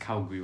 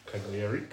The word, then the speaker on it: Cagliari: Because he got 3 assists in the preseason game against Calgary, whatever their team's called. – Cagliari.